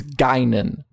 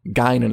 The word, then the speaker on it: Guinan: We're being told by Rizzo that it's Guinan. Guinan is how you pronounce it. – Guinan. Thank you. I knew I was probably saying it wrong.